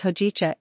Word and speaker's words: hojicha: The makron on ho simply indicates a long vowel sound, but it loses its purpose and becomes neutralized when translated into English. Therefore, the whole word is spelled as hojicha in English.